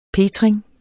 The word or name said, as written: petring